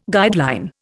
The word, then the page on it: guideline: Indian